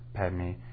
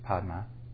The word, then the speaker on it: Padma: Like Om Mani Padme Hum, Mani, Jewel, Padme. It's not actually how it was pronounced. It was Padma.